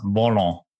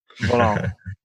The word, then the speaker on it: Volant: Volant. – Volant.